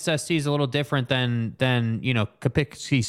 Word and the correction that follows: Quinnipiac: Quinnipiac? – Yeah.